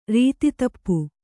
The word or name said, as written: ರೀತಿತಪ್ಪು